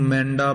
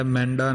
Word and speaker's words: मैडा: मेंडा पाठ ज्यादातर उच्चारण किया जाता है, मेंडा नहीं कहना, मैडा ही उच्चारण करना बिंदी तो रहत।